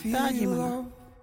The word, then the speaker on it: Thaajimanha: the Wajiri word is Dajimana.